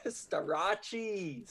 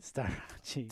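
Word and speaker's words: Starocci: Startucci at three and Labriola at four. – Wait, what did you say his name was? – Startucci? – Staracci. – Staracci.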